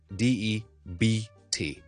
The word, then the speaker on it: debt: 另外一个我哋好常讲讲到 silent letter 嘅字咧，亦都系一个 silent B，就系 debt， D E B T， debt 一个欠债咧 debt。所以我哋通常咧，我哋唔会读 debt， OK 唔系 debt，个 B 系完全唔需要嚟嘅，就咁读，可以当系 D E T T 咁啊 debt。